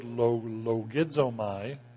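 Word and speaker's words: logizomai (λογίζομαι): It's a Greek word, logizomai.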